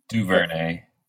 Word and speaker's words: DuVernay: DuVernay.